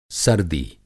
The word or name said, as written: सर्दी